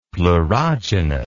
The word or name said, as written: pleurogenous